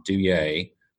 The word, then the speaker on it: Deuillet: Let me provide uh, my email address is my name Chris dot Deuillet.